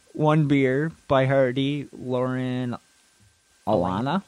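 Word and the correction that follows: Alaina: Elena. – Elena, something like that.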